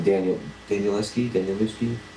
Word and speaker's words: Danielewski: I've seen him once do a reading, and Danielewski, is that how you say it? – Yep, Mark, that is.